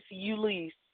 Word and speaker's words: Ulice: It's Ulice.